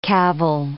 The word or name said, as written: cavil